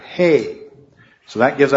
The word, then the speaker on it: He: In English it would be the H sound, but it's the letter He. And if you see in your Bibles, most Bibles will have that at the top. You see the word, the H-E, that's pronounced He.